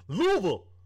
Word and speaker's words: Louisville: Louisville. – You pronounced it correctly, too. They get mad about that.